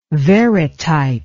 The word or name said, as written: varitype